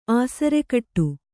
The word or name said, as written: ಆಸರಗೇಡಿ